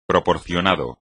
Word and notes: proporcionado: espagnol

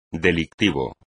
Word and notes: delictivo: espagnol

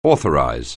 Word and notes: authorize: anglais

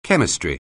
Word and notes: chemistry: English

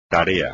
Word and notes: tarea: espagnol

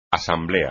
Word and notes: asamblea: espagnol

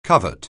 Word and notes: covert: anglais

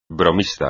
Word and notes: bromista: espagnol

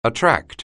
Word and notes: attract: anglais

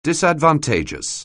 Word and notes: disadvantageous: anglais

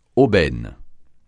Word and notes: aubaine: francais

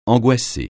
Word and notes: angoissé: francais

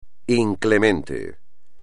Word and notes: inclemente: espagnol

